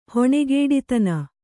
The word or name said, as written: ಹೊಣೆಗೇಡಿತನ